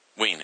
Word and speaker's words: Wein: Yeah. Ween. Okay. Great. Thank you. It's like my little personal – it's my ritual. I need you to correct me.